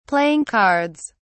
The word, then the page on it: playing cards: British English